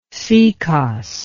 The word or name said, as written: sekos